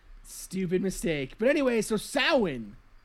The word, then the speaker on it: Samhain: In that episode I said that the Irish festival of Halloween was known as Samhain. It's actually pronounced Samhain.